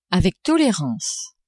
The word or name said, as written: avec tolerance